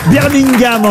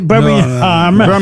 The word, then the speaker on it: Birmingham: Birmingham, en tout cas. On ne doit pas prononcer le G, hein, il paraît.